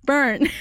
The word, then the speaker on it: Byrne: How do you say that one? – Burn. Is it B-Y-R-N-E? – Pero con la Y. When there's a Y. Okay, Tom Byrne. Oh, yeah. Like Gabriel Byrne.